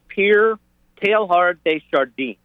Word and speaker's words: Pierre Teilhard de Chardin: The question is about Pierre Teilhard de Chardin.